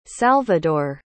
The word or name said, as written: salvidor